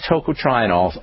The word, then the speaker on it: tocotrienol: which is unfortunate because these tocotrienols are really, really, really powerful molecules.